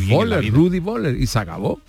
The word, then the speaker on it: Völler: Y acabado en ER no era E, era A. Entonces, lo que tú decías, Rudi Völler, que todo el mundo dice, ahí está Rudi Völler, era Rudi, porque lo pronunciaba así, Rudi, Fela.